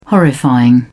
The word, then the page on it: horrifying: British English